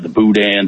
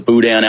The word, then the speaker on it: boudin: Well, and by the way, boudin is Boudin is a silent N. I have yet to be able to pronounce the word with a silent N yet.